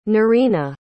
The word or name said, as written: noreena